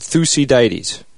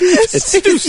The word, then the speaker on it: Thucydides: I read a ton, and I found a little interesting quote by a ancient man named Thucydides. Is that pronounced correctly? – No, not even no. – close. – Thucydides? – It's Thucydides.